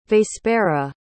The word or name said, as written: vespera